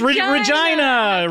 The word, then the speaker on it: Regina: It's Regina. Rhymes with vagina.